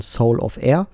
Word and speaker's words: Solovair: Solovair wird er geschrieben, aber ausgesprochen Soul of Air.